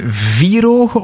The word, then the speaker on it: vierogen: Is het vierogen of vierogen? – Vierogen. De klemtoon ligt op vier.